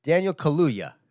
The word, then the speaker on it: Kaluuya: How the fuck do you say his name? Kaluuya. Man, poor man. I'm just butchering his name. – You know? – Daniel Kaluuya. I said it right at the end there. Kaluuya. It says two U's, man.